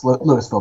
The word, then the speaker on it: Louisville: And, you know, they've got, they're focusing on toy stores in Las Vegas, Chicago, New York, Louisville, Texas. How do you pronounce it? – Yes. Louisville, Texas.